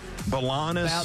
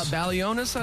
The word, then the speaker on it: Balionis: And I saw where, you know, the girl on CBS, Amanda Balanis. – Balionis, I think. Yeah. – Balanis. Like they've always pronounced it that way. It's spelled differently.